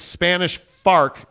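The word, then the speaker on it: Spanish Fork: Jeremy Bolton says it's Spanish Fark. Say it right.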